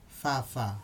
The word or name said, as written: fafa